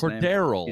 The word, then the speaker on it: Cordarrelle: Still don't know how to really pronounce his first Cordarrelle.